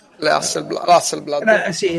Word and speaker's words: Hasselblad: Hasselblad.